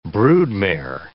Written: broodmare

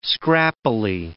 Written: scrappily

